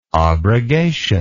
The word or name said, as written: obrogation